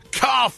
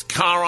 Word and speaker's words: Kahr: Car Firearms Group is a proud sponsor of this segment. It's spelled K-A-H-R. It's Car Arms, it's Magnum Research, the guys with the Deagles, the Desert Eagles, and Auto Ordnance, the modern version of the Tommy Gun.